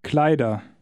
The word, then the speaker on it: Kleider: Kleider. – Notice the pronunciation of the ER ending. The R is just suggested, not pronounced very clearly.